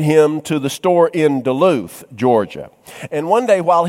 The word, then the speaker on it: Duluth: And they had a store in Cumming, and they had a store in Duluth, or as we country boys used to pronounce it, Duluth.